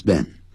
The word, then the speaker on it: been: How long has this been? How long has this been? B E E N. 我们中国人很多人会念成 been, 不是 been, 它是短音节语，这个元音 it been, been, been, I've been in Beijing for two years.